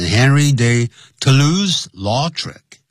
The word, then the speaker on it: Toulouse-Lautrec: 好，通常呢，就是直接就会念成 Henry de Toulouse-Lautrec，这是英文的念法，Henry de Toulouse-Lautrec captured it，他就捕捉到了这个画面了，在什么地方呢？In many of his paintings，在他许多的画作里头啊。